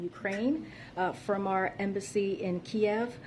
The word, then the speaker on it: Kyiv: From the capital of Kyiv to Lviv? I don't know how to... I'm butchering these names, but... – Kyiv? – Yeah.